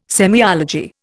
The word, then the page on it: semiology: Indian